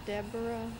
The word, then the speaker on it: Deborah: My name is Deborah Sherlene Cahill. It's spelled D-E-B-O-R-A-H-S-H-I-R-L-E-N-E-C-A-H-I-L-L.